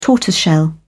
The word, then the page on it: tortoiseshell: British English